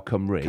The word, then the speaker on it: Cymru: And I made a great joke, but I had to check how you pronounce Cymru, otherwise crocodile Cymru. – Cymru. – The Crocodile Welsh.